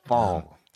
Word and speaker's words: Pfol: Fall. P-F-O-L. Silent P. A rarity.